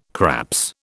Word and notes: craps: British English

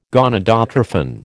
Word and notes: gonadotrophin: American English

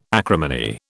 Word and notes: acrimony: British English

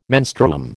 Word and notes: menstruum: American English

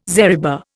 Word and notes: zariba: Indian

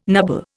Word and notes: nubble: Indian

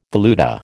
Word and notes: valuta: American English

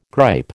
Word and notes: gripe: American English

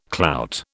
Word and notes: clout: British English

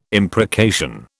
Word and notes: imprecation: British English

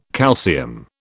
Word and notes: calcium: British English

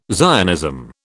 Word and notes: Zionism: British English

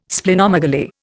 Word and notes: Splenomegaly: Indian

